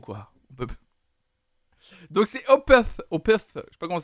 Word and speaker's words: Opeth: Alors je pense pas que ça se prononce Opet. Je pense que c'est Opeth parce que c'est O P E T H.